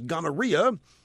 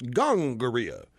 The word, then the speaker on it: gonorrhea: More likely, they argue, the other disease was gonorrhea, which my health teacher in junior high pronounced gongorrhea.